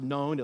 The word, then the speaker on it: None: It's pronounced known. It looks like none.